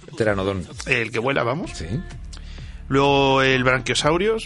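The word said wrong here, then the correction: Braquiosaurio: de toda la vida, el cuello largo. Ah, ¿eh? El piecito. – Brachiosaurio. – Brachiosaurio.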